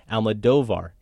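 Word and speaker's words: Almodóvar: We got this email, many emails like this, but I want to share this one from Ian in Barbados, who writes, Adam, you are stressing Don Pedro's name correctly, Almodovar. It has an acute accent on the second O. Where you see an accent in Spanish, it is always on the stressed syllable.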